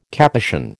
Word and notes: Capuchin: American English

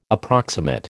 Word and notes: approximate: American English

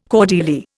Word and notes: cordelier: Indian

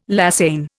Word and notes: lasagne: Indian

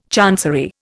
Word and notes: chancery: Indian

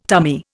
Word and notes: tummy: Indian

